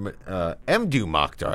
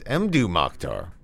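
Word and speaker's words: Mdou Moctar: And starting off that set, we heard Mdu Mokhtar. I always say his name incorrectly. It's Mdu Mokhtar with a track called Tarhataste.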